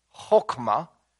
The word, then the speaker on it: chokmah: The word wisdom comes from a Hebrew word that is pronounced chokmah. It's got that guttural on the front end, so you got to a little bit. Chokmah.